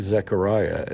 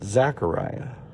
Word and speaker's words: Zechariah: And here's, it's actually pronounced, it's like spelt Z-E-C-H-A-R-I-A-H. In America, certainly, a lot of times we'll see Zechariah and we'll see it as an A, like Z-A-C-H, and we'll pronounce it Zechariah. But it's actually Zechariah. Just FYI for you.